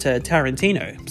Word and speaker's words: Tarantino: By the way, anyone asking about the pronunciation of Tarantino, One person, one of my friends, told me it might be Tarantino, but then I looked back at a promotion that Quentin was doing and he pronounced it Tarantino. So there we go. It is all good now. That debate solved.